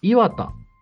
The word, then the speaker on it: 岩田: 1 期目は岩田、作品では渋谷と同じアクセントでしたが、つまり岩田ってことですね。 – あ、はいはいはい。 – 地元では名古屋と同じ発音です。